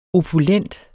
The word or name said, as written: opulent